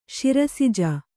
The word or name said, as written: ಸರಸಿಜ